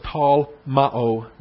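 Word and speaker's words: tall ma'o (τολμάω): So it's tall ma'o with a long O at the end.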